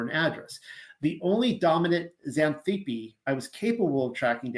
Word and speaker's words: Xanthippe: but then a woman by the name of Xanthippe takes the helm of this passage. The X is pronounced like a Z.